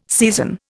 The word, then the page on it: season: Indian